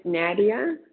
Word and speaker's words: Nadia: And I was in the beginning as Nadia E. – What was your first name again? – It's Nadia. – Oh, how do you spell that, Nadia? – N-A-D-I-A.